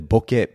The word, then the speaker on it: boke: And, and Anesha, I'm not sure if you realize this as well, but Bokeh is originally a Japanese word, boke, and I actually grew up in Japan, so I should be pronouncing it that way.